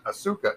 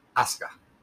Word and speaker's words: Asuka: That vowel that's in between there, between that S and that K is gone. It's not pronounced. So it's not Asuka, it's Asuka. And which is,